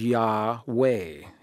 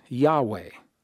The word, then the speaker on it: Yahweh: However, the overwhelming majority of scholarly opinion believes that the V is pronounced as a W. And in English, if we are to write this now from left to right, YHWH, it is believed that the name was pronounced as Yahweh, Yahweh, Yahweh.